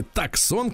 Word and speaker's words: Tucson: Hyundai Tucson или Таксон, как пишется, э, Jeep Compass, Jeep Renegade, американские машины, Mazda CX-5 и Mitsubishi Eclipse Cross.